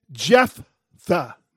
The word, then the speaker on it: Jephthah: It's pronounced Jephthah. Not to be confused with Jethro of the Beverly Hillbillies, Jephthah.